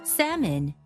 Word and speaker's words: salmon: Salmon, 연어 같은 경우 L 발음이 묵음이었습니다.